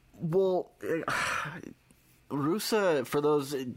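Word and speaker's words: Ruse: I mean, all I know about Dunaf Rus, if I'm pronouncing it correctly, is... – Rusa. – Rusa.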